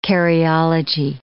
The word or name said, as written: karyology